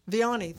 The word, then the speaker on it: Vianney: I've heard it pronounced both ways. – I've always heard Vianney. – Vianney, thank you. I want to do it justice.